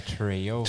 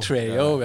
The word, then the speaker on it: Trejo: Danny Trejo. – Trejo ja.